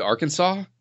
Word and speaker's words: Arkansas: The Arkansas? – Is that how you pronounce it? – Yes.